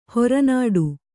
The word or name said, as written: ಹೊರನೋಟ